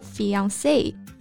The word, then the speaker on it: fiancé: 那这个表达呢，大家肯定在电影里面经常听到，未婚夫 fiance，未婚妻 fiance。 – That's right，这两个词都是来自于法语，虽然说拼写不一样，但是读音是一样的。